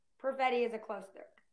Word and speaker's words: Perfetti: It's Perfetti.